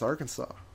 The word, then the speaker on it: Arkansas: We do have Arkansas. – No, that's Arkansas. – Oh. – That's, but, all right. – But it's spelled Arkansas.